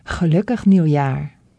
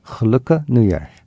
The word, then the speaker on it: Gelukkig Nieuwjaar: Gelukkig Nieuwjaar. – Gelukkig Nieuwjaar.